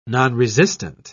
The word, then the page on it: nonresistant: British English